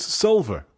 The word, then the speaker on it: Solver: Okay, and they have accents, so it's Solver. Good. We said it right.